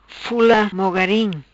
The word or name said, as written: Fula Mogarim